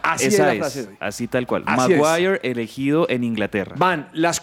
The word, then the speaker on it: Maguire: Usted que es un hombre que pronuncia también en inglés, Maguire. Maguire, sí, Maguire.